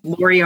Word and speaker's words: Lori: So Lori Armstrong on LinkedIn, just search and you'll find me.